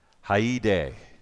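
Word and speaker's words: haide (χαῖρε): It's haide, okay? You might pronounce it in English as chair.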